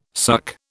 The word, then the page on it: suk: British English